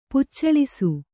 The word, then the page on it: ಪೆಚ್ಚಳಿಸು: pecaḷisu